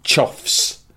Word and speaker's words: choughs: And something called choughs, which sounds a little bit like a cross between a cough and a chode. It's spelled C-H-O-U-G-H-S.